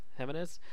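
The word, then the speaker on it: Jiménez: And sorry if I mispronounced it, Phil. – It's probably Jimenez. – Jimenez.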